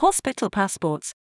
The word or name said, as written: hospital passports